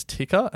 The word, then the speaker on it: TIKR: The other one that we're excited about, both of them, but the other one is Ticker, and it's spelled TIKR.